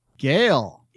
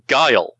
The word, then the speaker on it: GAIL: Yeah, they are spelled G-A-I-L. And how would you pronounce that? – Gale. – But they don't pronounce it that way. It's pronounced guile.